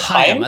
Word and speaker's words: Haim: You know his connection to the Haim girls are what is Haim? I thought it was like it's two syllables, like Haim. Like, but yeah. It's definitely two syllables, at least in interviews that they've said. – But his connection to them is basically their mom was like his art teacher.